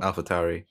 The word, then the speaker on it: AlphaTauri: And then Alpha, a team called AlphaTauri. I don't think I pronounced it right, but still. – AlphaTauri. – Yeah, Tauri.